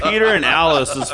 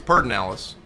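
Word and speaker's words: Pedernales: I was like, how do you come up with Peter and Alice's Falls? – Well, if you live in the hill country, it's Pernalis.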